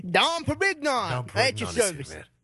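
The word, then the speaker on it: Dom Pérignon: Is that how you say it? – Dom Perignon. – Oh, right.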